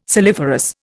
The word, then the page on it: saliferous: Indian